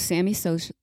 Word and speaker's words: Sammy Sosa: Well, he did a commercial with Sammy Sosa.